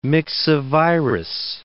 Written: myxovirus

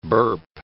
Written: burp

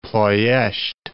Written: Ploesti

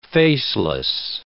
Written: faceless

